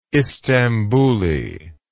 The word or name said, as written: istambuli